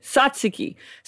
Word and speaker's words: tzatziki: Yeah, tzatziki.